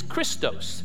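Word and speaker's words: Christos (Χριστός): The word in Greek for kindness is krestos. If you spell it in English, it's C-H-R-E-S-T-O, krestos. And the word for Christ is Christos, C-H-R-I-S-T-O.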